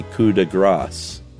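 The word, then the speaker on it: coup de grâce: The Arctic Fox episode is the latest, and Josh said the phrase coup de grace, where he correctly left the P silent, but incorrectly said fat. Gras in French is fat, with a silent last letter like foie gras, instead of grasse. Silent E, but a soft C. Loosely sounds like grass. Doubtless you were doing your best, but foie gras is a decidedly different thing from a coup de grasse.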